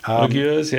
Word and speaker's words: Uyghurs: And currently in China, we've got human rights groups who believe China has detained more than a million, I don't know if I'm pronouncing this right, Uyghurs? – Uyghurs, yeah.